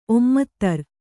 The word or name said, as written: ಒಮ್ಮತ್ತರ್